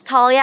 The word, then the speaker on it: Talia: My name is Talia Abbe, T A L I A A B B E, and my reality is staying home with my friends.